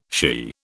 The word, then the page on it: shea: British English